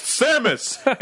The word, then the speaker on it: Samus: It is pronounced Samus, and she does not speak.